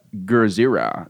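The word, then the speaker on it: Gojira: Gurzira. That's how it's pronounced.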